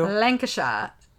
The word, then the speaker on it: Lancashire: Lancashire. In parentheses, when pronounced with a proper northern accent. Nice. Think Jon Snow from A Game of Thrones. – Oh. – It's in the northwest of England. Lancashire.